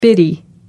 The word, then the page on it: bitty: American English